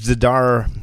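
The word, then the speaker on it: Zdar: And Philippe Zadar, did I pronounce his last name correctly? – That's beautiful.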